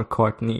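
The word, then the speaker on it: Cortnay: I'm always pronouncing him like the same way as you would pronounce Courtney Cox or Courtney Love. – Yeah, I think it's just Courtney. Just Courtney Penrose.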